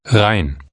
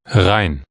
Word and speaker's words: Rhein: The name of the Rhine River is pronounced Rhein. Rhein.